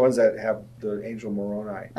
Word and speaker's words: Moroni: We had missionaries tell us from their Bible that Moroni, did I say that right? – Moroni, yep.